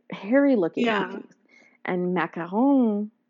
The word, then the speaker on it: macaron: How about, like, macarons? Macarons? I don't know how to say it. – Oh, okay. So it is, it's a macaron. – Okay. – So it's not macaroon,